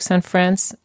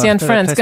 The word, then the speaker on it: Cianfrance: also der Derek Cianfrance, wie wird er ausgesprochen? Derek Cianfrance. – Ach, er hat das gemacht. – Cianfrance,